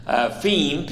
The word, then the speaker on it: pheme: And then the last word is the word pheme, P-H-E-M-E, both E's being long E's.